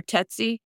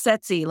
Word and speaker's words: Setsy: And I wasn't pronouncing her name correctly for a minute. And then I did my interview with Dolly and I think I was calling her Tetsy. And Dolly was like, no, it's setzy, like sexy.